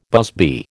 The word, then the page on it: busby: American English